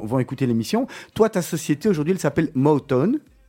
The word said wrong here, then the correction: Motown: Motown, prononce bien, s'il te plaît. Motown Oui, presque. Ah, vas-y, redis-nous. Motown. Motown. – M-O-T-O-W-N, inspiré du fameux label euh, musical Motown.